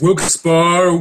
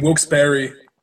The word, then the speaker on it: Wilkes-Barre: I mean, Wilkes-Barre, like a berry, like a strawberry. – Mm-hmm. – And again, Wilkes-Barre, like a grizzly bear.